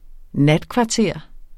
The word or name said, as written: NATKVARTERERNES